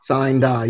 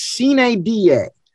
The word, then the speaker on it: sine die: Carla, we're just talking about the phrase sine die, which English people in America pronounce sine die, which means without setting a day, without a day being set.